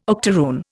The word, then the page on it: octaroon: Indian